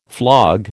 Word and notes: flog: American English

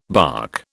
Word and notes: barque: British English

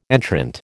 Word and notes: entrant: American English

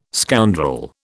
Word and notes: scoundrel: British English

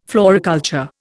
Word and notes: floriculture: Indian

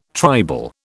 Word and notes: tribal: British English

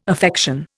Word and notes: affection: Indian

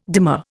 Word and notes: demur: Indian

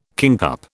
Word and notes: kingcup: British English

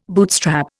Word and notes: bootstrap: Indian

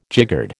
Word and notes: jiggered: American English

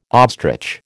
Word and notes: ostrich: American English

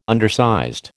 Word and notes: undersized: American English

